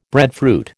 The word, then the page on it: breadfruit: American English